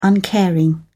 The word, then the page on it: uncaring: British English